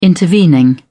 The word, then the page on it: intervening: British English